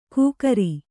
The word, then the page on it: ಕುಕ್ರಿ: kukri